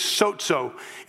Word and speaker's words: sozo: The word here for saved is sozo. It's S-O-Z-O is the closest way we could say it, spell it in English.